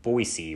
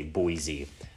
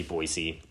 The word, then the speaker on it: Boise: Natives and longtime residents tend to pronounce it Boise, while others, uh, outsiders, usually say Boise. Uh, it's absolutely Boise. If you come to Idaho, um, don't say Boise.